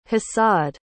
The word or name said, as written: hasad